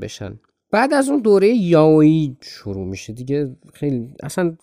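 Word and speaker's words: یاویی (弥生): کافیه دهنتون رو باز کنید و یا رو بگید و بچرخونید تو دهنتون این میشه دیگه یاویی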